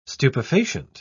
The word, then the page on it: stupefacient: British English